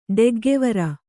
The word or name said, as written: ಡೆಗ್ಗೆವರ